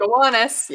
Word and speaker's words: Gowanus: Arts Gowanus, is that how you say it? – Gowanus, yep.